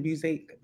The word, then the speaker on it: Busiek: You know, I still think it came out of nowhere, and it pretty much undid a lot of what Kurt Busiek and is that how you say his name? Busiek. – Busiek.